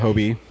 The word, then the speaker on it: Hobie: Is it Hobie? Hobie? – Yeah, Hobie.